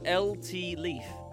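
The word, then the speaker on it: Leif: Is it It's LT Leaf. – LT Leaf. All right, so... – Leaf as in Leaf Erickson, not Leaf as in the pop tree.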